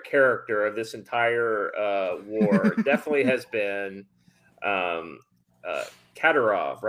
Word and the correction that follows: Kadyrov: right? Am I saying it right? Katerov. I have a history of not saying that name right. I think that's right. – Anyway, Kadyrov.